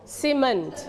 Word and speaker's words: cement: เดี๋ยวเราต้อง stretch เสียงให้ถูกต้องนะคะ We stretch on the second syllable พยางค์ที่สอง cement.